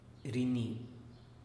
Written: rini

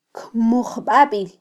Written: kmujbabil

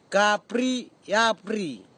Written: kapri iapri

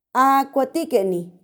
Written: akuatikeni

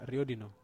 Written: riodino

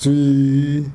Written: tswii

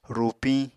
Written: rupiñ

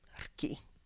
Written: rki

